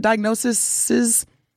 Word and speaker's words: diagnoses: Truth Nation, we want to celebrate the survivors of breast cancer and highlight their stories of fighting and thriving through their diagnoses. Please, is that how you say it? Diagnoses? Okay. – Diagnoses.